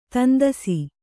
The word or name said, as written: ತಂದಸಿ